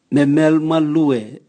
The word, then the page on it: memel malue: Raga